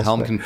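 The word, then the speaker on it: Helmcken: The British add an extra E, so it becomes Helmkin Falls. – Okay. – But proper North American pronunciation, in my view, is Helmkin Falls. – Helmkin Falls.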